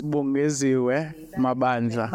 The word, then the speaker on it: Bongeziwe Mabandla: How do I say it? – It's Bongeziwe Mabandla.